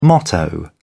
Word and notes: motto: British English

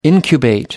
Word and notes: incubate: American English

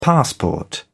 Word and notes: passport: British English

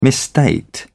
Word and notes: misstate: British English

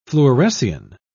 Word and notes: fluorescein: British English

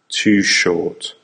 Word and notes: too short: American English

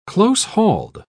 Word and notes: close-hauled: British English

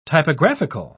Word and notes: typographical: British English

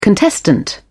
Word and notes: contestant: British English

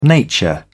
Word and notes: nature: British English

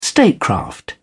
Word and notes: statecraft: British English

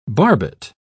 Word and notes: barbet: British English

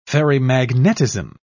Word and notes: ferrimagnetism: British English

